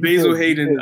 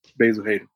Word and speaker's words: Basil Hayden: Someone asks me about my favorite bourbon, I say Basil Hayden. – Yeah. – You don't say it like that, though. You say Basil Hayden. – Basil Hayden, I tell you, what.